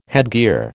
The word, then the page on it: headgear: American English